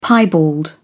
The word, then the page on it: piebald: British English